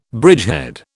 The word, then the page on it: bridgehead: British English